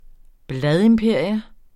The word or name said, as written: bladimperium